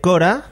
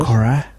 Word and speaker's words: Korah: Bueno, pues recomendamos el canal de Cora, de espacio Cora con K terminado en H. Así es. Cora. Muy bien.